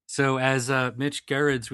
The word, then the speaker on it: Gerrits: If this kind of thing tickles, the G is silent. It's Gerrits.